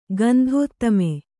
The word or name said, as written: ಗಂಧೋತ್ತಮೆ